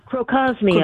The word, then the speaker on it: Crocosmia: Another one that I have just fallen in love with, and it's pretty inexpensive, that Croxomia, did I say that right? Croxomia? – Crocosmia. – Crocosmia, okay.